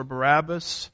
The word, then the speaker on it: Barabbas: We've studied a little bit about this man Barabbas or Barabbas. Depends on which syllable gets the emphasis, as I like to say.